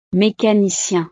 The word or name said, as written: mécanicien